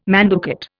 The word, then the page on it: manducate: Indian